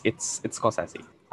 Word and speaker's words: Scorsese: No, it's Scorsese.